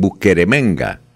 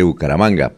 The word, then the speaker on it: Bucaramanga: unos dicen Buqueremenga, otros dicen Bumaracanga, ¿sí? Nosotros somos familiares, aquí, pues, todo dice Bucaramanga, pero pronuncia como cuando los niños, y lo digo con mi nieta que tenía como tres años y no, no era capaz de pronunciar Bucaramanga, así pronuncian en el exterior, Bucaramanga.